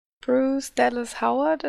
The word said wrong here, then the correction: Bryce Dallas Howard: oder so. Bryce. – Bryce, glaube ich. – Bryce Dallas Howard, die ja auch aus Jurassic World und sowas kennen.